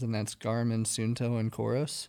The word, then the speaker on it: Coros: Coros. – How do you spell that? C O R O S.